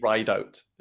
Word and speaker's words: Ridout: Yeah, people think it's Ridout, like French, or the other one I get is Ridout, but Ridout? It's actually Ridout.